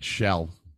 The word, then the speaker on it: Aschell: Wait, Shell. Yeah. – Not asshole. It's, no, it's spelled A S C H E L L, but the pronunciation is shell.